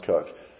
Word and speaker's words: Coke: It's gonna be spelled like Coke, C-O-K-E, but when we see it, it's actually Thomas Cook.